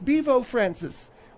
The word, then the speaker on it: Bevo Francis: A moment ago, we were talking about this Bevo Francis, which, by the way, that's how it's pronounced.